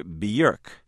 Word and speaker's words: Björk: now singer and composer Björk. That's evidently how you should pronounce the name, spelled B-J-O-R-K, and she's about to tell you what you must hear.